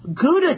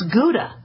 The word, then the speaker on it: Gouda: Gouda cheese. That's not how you pronounce Gouda. It's Gouda. That's apparently from the Netherlands. It's named after the city in the Netherlands, and they pronounce it with an H.